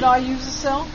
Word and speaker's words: Iusacell: Isn't it I use a cell? – Well, that's how you say it down here. – Yeah, I-U-S-A-C-E-L-L.